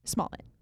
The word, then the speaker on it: Smollett: Smollett? – Smollett.